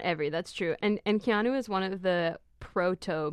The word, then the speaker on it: Keanu: It's Keanu.